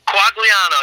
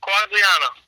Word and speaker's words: Quagliano: Is that how you say it? The Italian way? – Quagliano, yep. Quagliano.